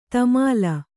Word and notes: ತಮಾಲ: tamāla

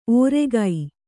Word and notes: ಓರೆಗೈ: Kannada

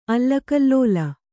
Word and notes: ಅಲ್ಲಕಲ್ಲೋಲ: allakallōla

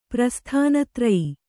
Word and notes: ಪ್ರಸ್ಥಾನತೂರ್ಯ: prasthana tūrya

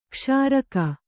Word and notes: ಕ್ಷೀರಕ: kannada

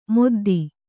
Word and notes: ಮೊದ್ದಿ: moddi

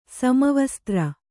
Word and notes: ಸಮವಸ್ತ್ರ: kannada

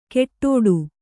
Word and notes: ಕೆಟ್ಟೋಡು: keṭṭōḍu

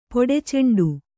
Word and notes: ಪೊಡೆಚಂಡು: poḍe caṇḍu

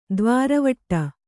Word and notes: ದ್ವಾರವಟ್ಟ: dvāra vaṭṭa

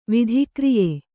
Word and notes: ವಿಧಿಕ್ರಿಯೆ: kannada

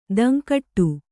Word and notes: ಧಿಂಕಿಡು: kannada

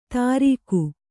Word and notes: ತರೀಖು: Kannada

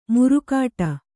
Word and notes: ಮುರುಕಾಟ: murukāṭa

